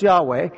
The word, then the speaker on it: Yahweh: Well, first of all, the pronunciation is not Jehovah, it's Yahweh.